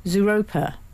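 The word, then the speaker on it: Zooropa: I heard the whole of the U2 Zeropa tour. – Mm. – Did I say that right? Zeropa. – Yeah. Yeah, I think so.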